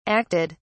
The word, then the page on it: acted: British English